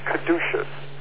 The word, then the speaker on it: caduceus: It's pronounced Caduceus.